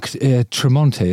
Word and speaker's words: Tremonti: So learning to play for real, presumably that's like, because you guys, it's Mark Tremonti, isn't it?